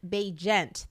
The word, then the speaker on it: Bagent: So Chicago QB Tyson Bagent. That's how you say it.